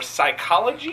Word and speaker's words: psychology: Silent P's. Why? What? Are we talking about in words? – Yes. Yeah, that's a good topic. – Like psoriasis? – Yes. Like, what's the point? – Or psychology?